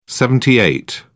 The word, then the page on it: seventy eight: British English